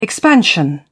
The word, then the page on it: expansion: British English